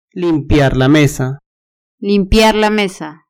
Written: limpiar la mesa

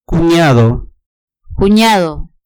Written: cuniado

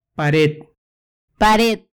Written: pared

